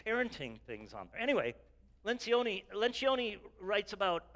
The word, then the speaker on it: Lencioni: It's phonetically like Lencioni, L-E-N-C-I-O-N-I.